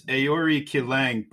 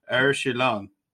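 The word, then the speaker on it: Aoriqileng: So now if you read this in straight American terms, it's Aori Kilang, but it's Aori Shilang when you want to pronounce it correctly.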